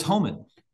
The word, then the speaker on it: Homann: It is Homann.